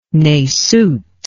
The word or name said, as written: nasute